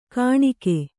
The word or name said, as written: ಕಾಣಿಕೆ